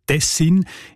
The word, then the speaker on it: Tessin: Und auch im Hochdeutschen sieht man Tessin. Die Variante mit der Betonung auf den ersten Silbe und mit kurzem «i», also Tessin, ist seltener.